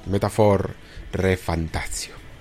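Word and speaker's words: Metaphor: ReFantazio: Eh, no sabíamos exactamente lo que estaba pasando con este Project Refantasy, que se ha transformado en este, eh, a ver si lo digo bien, Metaphor Refantasio, es así, ¿no? Me parece. Bueno, madre mía, madre mía, lo voy a buscar por si acaso, porque no la, quiero liar. Sí, sí, lo he dicho bien, Metaphor Refantasio, madre mía.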